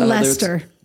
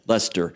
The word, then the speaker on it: Leicester: But University of Leicester, is that Leicester? – Leicester.